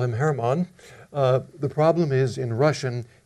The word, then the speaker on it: Hermann: And it's usually spelled with an H, but pronounced in Russian as German. And I'm trying to be excruciatingly correct here, tonight, so I will, that's the difference. In the handout I gave you, I spelled the name with an H, okay, but I'll be saying it with a G, hard G if I can remember.